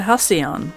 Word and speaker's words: Halcyon: Halcyon? – A Halcyon.